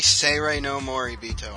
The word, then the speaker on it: Seirei no Moribito: Moving on, Media Blasters rescues Surreal no Morabito License. Did I even say that correctly? – It's probably Seirei no Moribito, I think. I think it's Seirei.